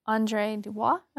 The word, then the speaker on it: André Dubois: And you quoted André Dubois, am I mm-hmm.